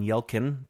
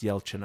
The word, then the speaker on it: Yelchin: For the longest time, I called him Anton Yelkin, so it's pronounced Yelchin, apparently.